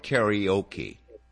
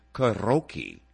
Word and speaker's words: karaoke: And over here, we call it karaoke. Okay. The proper pronunciation is karaoke.